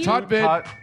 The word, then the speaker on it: Tutvid: Is it Tutvid? It's spelled T-U-T. Tutvid. It's tutorial videos.